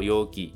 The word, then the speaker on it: 余儀: 那汉字写作鱼宜啊，念作 Yogi，这个字通常以否定的方式存在啊，念作 Yogi nai，就是没有办法，迫于无奈，不得已。